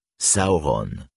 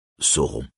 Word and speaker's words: Sauron: La première syllabe d'aulé se prononce donc comme l'anglais howl et la première syllabe de Sauron comme l'anglais saur, ce qui donne en français Sauron. Et non, Sauron.